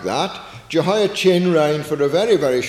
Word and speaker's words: Jehoiachin: Then later on, in the reign of his son Jehoiachin, I pronounce it like that so that we distinguish it.